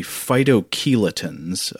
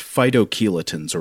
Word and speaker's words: phytochelatins: and I think this would be phytochelatins, if it'd be pronounced kind of like the word chelation, which is about bonding to metals, and that's also what these things do, I believe.